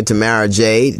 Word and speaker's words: Tamara Jade: Tamara Jade. Did I say that right? Jasmine, is that right? – Tomorrow? Yeah, you're right.